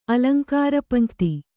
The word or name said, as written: ಅಲಂಕಾರಪಂಕ್ತಿ